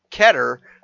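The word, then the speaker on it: Qatar: So, uh, and the World Cup is being played in Ke- uh, in Keter, is how I've been told is pronounced, uh, and uh, apparently there's accusations of bribery and all kinds of other shenanigans.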